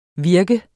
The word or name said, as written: virke